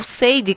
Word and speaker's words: Sade: remeteu muito a cantora Sade Adu, ou Seide, como muita gente pronuncia também.